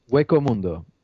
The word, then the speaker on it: Hueco Mundo: And and basically they kidnapped Orihime and so they have to go into Hiyosamondo, or I can't pronounce it? – Hueco Mundo. Okay.